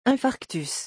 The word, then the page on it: infarctus: French